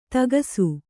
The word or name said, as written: ಥಕಾಸು